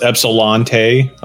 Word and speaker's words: Ypsilanti: I think it's Ypsilanti. – It's pronounced Ypsilante.